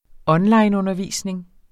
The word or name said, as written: onlineundervisning